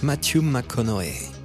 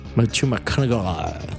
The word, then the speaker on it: Matthew McConaughey: Alors ça se prononce Matthew McConaughey. – Matthew McConaughey. – Avec son accent du sud des États-Unis.